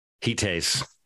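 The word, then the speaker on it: Heathaze: This Heathays? Heat haze. Heat haze. Thank heat, heat haze. If you think about heat it as haze. two separate words, okay.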